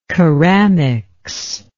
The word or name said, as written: keramics